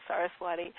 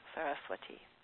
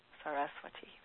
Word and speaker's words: Saraswati: with an English pronunciation, Saraswati, or if we're going to pronounce it more like Sanskrit, we would say Saraswati, Saraswati. So you might want to just repeat that to yourself, Saraswati. The accent is on the, not the next to the last, but before that syllable, Saraswati.